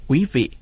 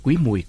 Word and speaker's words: Quý Mùi: Cuộc Trần Trọng Kim chép là ngày 7 tháng 10 năm Quý Mùi 1883, đọc là Quý Vị hay Quý Mùi cũng được.